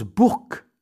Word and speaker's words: Bourg: Eh oui, on prononce Bourg avec un K, alors que ça s'écrit Bourg, B-O-U-R-G.